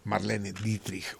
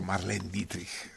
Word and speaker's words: Marlene Dietrich: Marlene Dietrich o Marlene Dietrich. de las dos maneras se pronuncia, a la francesa o a la alemana.